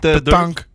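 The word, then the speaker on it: pétanque: Zaterdag in Veendal, de nationale hoe spreek je dat uit? – Petank. – Petank bij Le Sabon.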